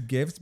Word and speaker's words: GIF: I think it's gifts because the I think the reason it's gifs is because the word is graphic. So hard G graphic, hard G GIF.